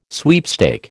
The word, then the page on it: sweepstake: American English